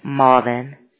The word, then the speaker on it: Marvin: Melvin, M E. – No, Marvin. – How do you spell it, please? – Hold on. M A R V I N. – Oh, Marvin, thank you.